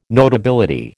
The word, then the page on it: notability: American English